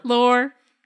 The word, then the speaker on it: lore: the lure. I don't know how to say the word. – Lore? – Lore. There we go.